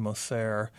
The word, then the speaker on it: Moser: Moser or M-O-S-E-R? – Moser. – Moser. – Yeah.